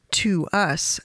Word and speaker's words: TWS: It's spelled TWS. The band name is To Us.